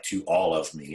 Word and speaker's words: Tuolumne: Like, Tuolumne is spelled really weird. – Yes. – It's like to all of me is how you say it.